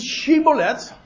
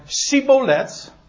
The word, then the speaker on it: Shibboleth: Nou, dan zeggen ze, zeg alsjeblieft Shibboleth, en dan zei Sibolet, aha, we weten meteen dat jij een Ephraimiet bent. Want eh, omdat hij het niet naar behoren kon uitspreken. En dan vielen ze meteen door de mand. Het was een, dat, ze konden dat paswoord gewoon niet correct uitspreken.